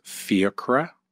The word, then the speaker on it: Fiachra: And my full name is Fiachra O'Sullivan.